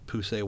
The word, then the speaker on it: Poussey: So, spoiler alert, if you haven't seen it, one of the characters, Poussey Washington...